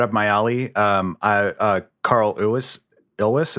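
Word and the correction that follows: Cary Elwes: Carrie Ellis.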